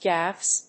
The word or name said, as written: gaffs